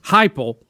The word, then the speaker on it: Heupel: Heupel, who was the University of Central Florida's football coach.